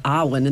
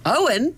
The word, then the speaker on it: Arwen: she'll say Arwen and they'll be like, Owen? it's Arwen.